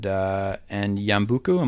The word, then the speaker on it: Yambuku: So I wonder if you could take us back to 1976 and Yambuku, am I saying that correctly? – I believe so, yes.